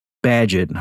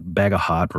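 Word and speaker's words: Bagehot: Actually, before I get into that, because it's kind of the big issue, let me just mention, so Walter Badgett, I think is how you pronounce his name, even though it looks like Bagahot or Bagahot. I think it's Badgett, was a famous commentator on central banking matters, financial matters in England in the late 1800s.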